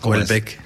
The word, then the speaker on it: Houellebecq: Ajá, Houellebecq.